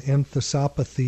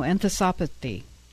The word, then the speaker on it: enthesopathy: what we call an enthesopathy, your favorite word. – I love that word. I learned how to say it now, enthesopathy.